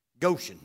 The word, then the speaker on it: Goshen: Goshen, that's how you say it, Goshen.